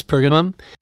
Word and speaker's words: Pergamum: Just to keep us all honest, let's uh, let's mention the seven churches: Ephesus, Smyrna, Smyrna, Pergamum. Is that how you pronounce it? Yes, Pergamum, Thyatira, Sardis, Philadelphia, and the lukewarm church, Laodicea.